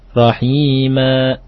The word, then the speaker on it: رحيما: طيب نعم رحيمanya mesti mustaqim akhi ya mustaqim kalimat rahima terus bacanya kuat rahima seperti itu